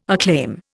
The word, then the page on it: acclaim: Indian